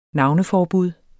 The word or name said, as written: navneforbud